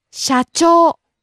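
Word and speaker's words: Sha-chou (社長): Sha-chou. – President. – Sha-chou. Sha-chou. – Rising on the second syllable, cho.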